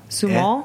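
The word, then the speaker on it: Sumoll: The Spanish grape, it's S-U-M-O-L-L. Sumol. – Eh? Oh, okay. – Sumol, yeah.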